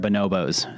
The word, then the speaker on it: Bonobos: He bought me a pair of bonobos.